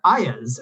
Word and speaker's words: Iuz: And again, a Greyhawk example, there's an evil creature in a land spelled I-U-Z. And all the years when I was a kid playing D&D, I pronounced that Ayaz.